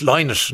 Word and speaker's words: Lynott: I was watching an interview you did with somebody and you said it's Lynott, not Lynott.